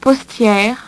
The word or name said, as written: postiere